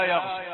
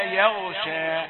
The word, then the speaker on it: يغشى: خطأ يقول إذا يغشى هكذا يعني يأتي جزء من التفخيم يؤدي الألف لأنه يستعد لنطق الياء خطأ مفخمة لكن قل إذا ألف مرققة ياء مرققة يغشى إذا يغشى